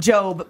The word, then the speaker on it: Gob: So he goes by Job. – Job, but it looks like Gob, so everyone calls him Gob, and he's a magician, and it's a whole thing.